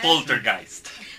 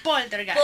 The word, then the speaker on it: Poltergeist: Poltergeist. – Poltergeist.